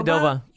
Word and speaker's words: Qdoba: Qdoba.